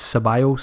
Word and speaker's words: Ceballos: Ceballos at Arsenal, thanks to everyone who sent me messages last week complaining about my Ceballos pronunciation. So I'm told it's Ceballos, so I'm going to try and get that right from now on.